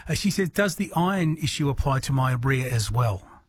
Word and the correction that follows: Meyer: So maybe a Meyer lemon, so Yeah.